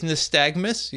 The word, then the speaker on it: nystagmus: nystagmus? – Oh, yeah. I think it's nystagmus, yeah.